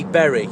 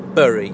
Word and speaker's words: Bury: Bury. When I'm in Manchester, I get told it's pronounced Bury. When I'm in Bury, hanging out with posh people, they tell me it's pronounced Bury. So, you decide!